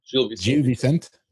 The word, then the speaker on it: Gil Vicente: Arouca beats Gil Vicente there. I don't know if that's how you say it, but Gil Vicente.